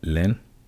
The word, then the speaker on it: Lens: On Saturday, we had Lens 2-1 over Bordeaux. It's actually Lens. I don't know why I always say Lens, because it's spelled Lens, but it's Lens.